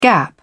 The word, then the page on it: gap: British English